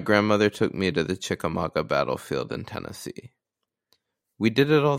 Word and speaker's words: Chickamauga: Yeah, and he had a possible sighting of green eyes from the Chickamauga, I think, according to the YouTube video I just watched to learn how to pronounce it.